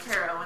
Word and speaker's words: tarot: I learned how to actually use tarot cards, and I learned that, yes, it's pronounced tarot, and I should stop being a little spite shit.